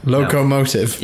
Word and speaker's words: locomotive: The Mokolotive? – No. – Did I say that right? Locomotive? – No. Yeah. – That's the one.